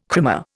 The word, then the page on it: krimmer: Indian